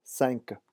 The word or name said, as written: cinq